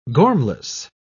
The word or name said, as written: gormless